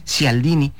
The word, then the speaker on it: Cialdini: No sé cómo se pronuncia bien, pero creo que es Cialdini.